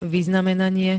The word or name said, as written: vyznamenanie